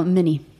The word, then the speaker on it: Mini: No, Mini. Mini. Så det blir, de tror att det är som Minnie Mouse.